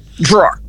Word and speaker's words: drawer: E gaveta, você não vai pronunciar o E, não é drawer, não é drawer. Uma sílaba só, você vê. World é como se eu tivesse duas sílabas, e drawer, olhando, você pensa que tem duas e tem uma só. Drawer, é como se fosse D-R-O-R, drawer.